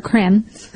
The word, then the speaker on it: Creme: Cream or creme? I'm not pronouncing things correctly today. – Creme. – creme, like creme brulee.